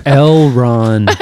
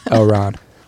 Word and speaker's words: L. Ron: It's L. Ron. – L. Ron Hubbard. I can see how you would get the mispronunciation, but L. Ron.